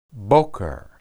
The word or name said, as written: boqer